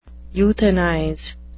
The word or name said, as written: euthanize